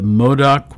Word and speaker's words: Modoc: 1873, a group of Modoc, M-O-D-O-C, warriors defeats the United States Army in the First Battle of the Stronghold. And they go on to use this word again, a part of the Modoc War, M-O-D-O-C.